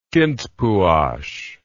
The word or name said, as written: Kintpuash